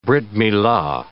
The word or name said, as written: Brit Milah